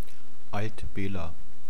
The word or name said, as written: Alt Bela